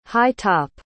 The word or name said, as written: high-top